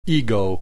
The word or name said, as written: ego